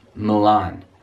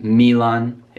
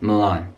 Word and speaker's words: Milan: And another Italian city, be careful with this pronunciation, Milan. Milan. – Milan. It's not Milan, it's not Milan, just Milan.